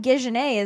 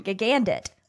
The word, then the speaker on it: Gigandet: Cam Gijene is from Twilight. – Is that how you say his name? – I don't know. I'm guessing. – Gijene? – Gigandet.